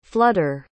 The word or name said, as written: flooder